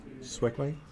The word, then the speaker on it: segue: Is that how it's pronounced? Segue? – Segue? – No, it is.